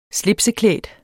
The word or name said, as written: slipseklædt